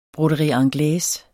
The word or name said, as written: broderie anglaise